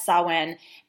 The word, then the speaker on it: Samhain: And it's written Samhain, pronounced completely differently as Samhain.